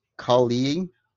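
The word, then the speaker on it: Calle: Her name is I'm gonna butcher this so hard. No, Sasha Kali. So it's spelled Sasha Kalle.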